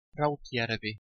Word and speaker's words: Rautjärvi: Rautjärvi.